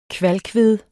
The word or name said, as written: kvalkved